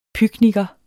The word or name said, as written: pykniker